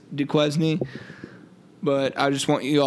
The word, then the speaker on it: Duquesne: But we were calling this team Duquesne, as Hutch so famously coined it, and it is pronounced Duquesne. Our buddy Leo reached out and corrected us.